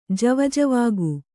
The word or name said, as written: ಜವಜವಾಗು